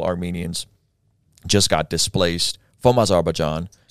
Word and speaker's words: Azerbaijan: Azerbaijan, Baku.